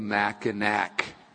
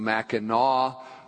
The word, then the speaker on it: Mackinac: Now, people that are not from there call it the Mackinac Bridge, but it properly is pronounced the Mackinac Bridge.